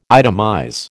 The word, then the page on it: itemize: American English